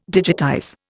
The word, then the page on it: digitize: Indian